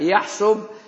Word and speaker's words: يحصب: والمؤلف هو عياض بن موسى ابن عياض بن عمرو اليحصبي السبتي، واليحصبي نسبة إلى بني يحصب، وهي قبيلة يمنية مثلثة الصاد، تنطق بضم الصاد وبفتحها أو بكسرها، يحصب ويحصب ويحصب.